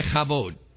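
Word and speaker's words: kavod: Everybody say chavod. If you didn't spit on the person in front of you, you didn't say it right. All right. There's a it's a real guttural language. There's a lot, there's a lot of phlegm involved.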